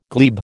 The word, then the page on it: glebe: American English